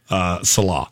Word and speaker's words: Saleh: Robert Saleh. Salah. How do you say his name? – Salah. – Salah.